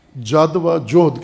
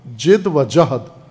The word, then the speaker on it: جد و جہد: لیکن فصیح تلفظ اس لفظ کا جہد ہے جد و جہد جد و جوہد نہ کہا کریں